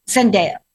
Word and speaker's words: Zendaya: Zendaya, I have talked about Zendaya since the very first day I mispronounced her name and said Zendaya. She is not Zendaya. Zendaya.